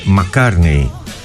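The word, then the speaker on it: Mark Carney: Η φίλη μου η Παρασκευή με διορθώνει και μου λέει το όνομα του Καναδού Πρωθυπουργού είναι Μακάρνεϊ. Εγώ το είπα σαν Μακάρνεϊ, όχι είναι Μακάρνεϊ.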